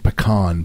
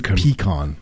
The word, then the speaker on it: pecan: I pronounce it pecan, but in the South they pronounce it pecan.